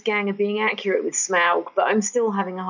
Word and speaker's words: Smaug: O Smaug.